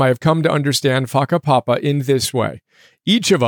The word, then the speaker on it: whakapapa: First big idea, whakapapa, which is spelled W-H-A-K-A-P-A-P-A, whakapapa. First big idea, whakapapa. It's a Maori term that is spelled W-H-A-K-A-P-A-P-A, whakapapa.